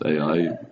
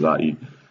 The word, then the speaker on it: Ai: They go to the next town, a little place called Ai, spelled Ai and pronounced Ai, unless you have the British version, which is Ai.